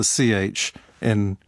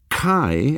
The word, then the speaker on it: chi: So the Greek letter that looks like an X or cross but is pronounced like a ch gets a symbolic meaning as it moves from Greek to Latin. That letter chi,